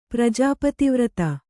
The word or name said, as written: ಪ್ರಜಾಪತಿವ್ರತ